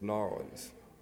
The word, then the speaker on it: New Orleans: I'm Southern, so I know how to say it. It's New Orleans.